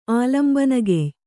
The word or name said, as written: ಆಲಂಬನಗೆಯ್